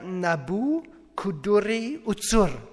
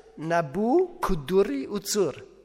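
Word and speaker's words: Nabû-kudurri-uṣur: Nebukadnezar, das ist babylonisch, heißt auf babylonisch ausgesprochen Nabu Kuduri Utsur. Nebukadnezar. Nochmals, Nabu Kuduri Utsur.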